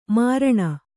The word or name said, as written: ಮರಣ